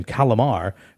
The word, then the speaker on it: Calamar: It's easy for me to pronounce this guy's last name, Calamar. It's basically Calamari without the I.